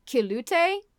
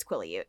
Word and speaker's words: Quileute: I was pronouncing it like Kilute. – Mm-hmm. – Like the U was like a diphthong. I was pronouncing it Kilute, and it's not. It's Quileute.